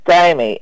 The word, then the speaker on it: Stamey: I think they spell R-A-N-D-A-L, I think. – Landon? – Randall. – Okay, and the last name? – Stamie, S-T-A-M-E-Y.